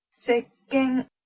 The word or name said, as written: sekken